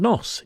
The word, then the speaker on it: Gnoss: Now, the young Scottish band Nos, G-N-O-S-S, well, I keep calling them young, but they must be getting older, have a new single out called Hjop.